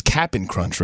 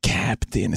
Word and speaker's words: Cap'n Crunch: And by the way, you know it's Cap'n Crunch, right? It's not like Captain. It's Cap'n Crunch.